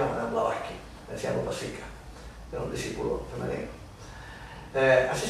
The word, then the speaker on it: Upasika: Upasika. U-P-A-S-I-K-A.